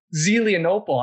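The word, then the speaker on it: Zelienople: And I have to ask you, did I butcher the name Zelenopely? How do you say it? – Zelenopely. – I wasn't even close.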